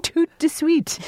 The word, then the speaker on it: tout de suite: What did you just say? It gets it there how? – Tout de suite. Dana, say it yourself. Do you just say tout de suite? – I think in general the duh is kind of elided in pronunciation.